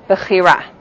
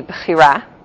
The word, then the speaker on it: bechira: So depending on how you pronounce it, if you're pronouncing it with an Israeli accent, as I often pronounce things, or the American, more yeshivish accent, as you might hear it more often from teachers in this class. So the Israeli accent would be bechira.